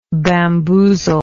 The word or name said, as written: bamboozler